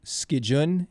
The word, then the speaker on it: Skicin: So in our writing system, it's actually S-K-I-C-I-N, but in the English phonetics, it would be more S-K-E-E-J-U-N. – S-K-E-E-J-U-N. And yeah. – So Skijun, is that how you pronounced it? – Skijun. So maybe S-K-E-E-J-I-N would be better.